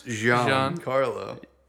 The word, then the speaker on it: Giancarlo: How do you pronounce that? – Is that Juan Carlo? – Well, it's Giancarlo. Yeah.